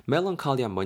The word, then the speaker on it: melancholy: M-E-L-A-N-C-H-O-L-Y 되겠습니다. melancholy한 뭐냐면요. 우리 왜 멜랑꼴리하다 그러죠? 그거예요, 그거. 그게 발음이 이제 melancholy 정도로 되고요.